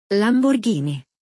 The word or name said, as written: Lamborghini